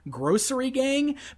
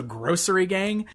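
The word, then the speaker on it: Grossery Gang: Grocery Gang, but it's spelled G-R-O-S-S, like gross... So, Grocery Gang.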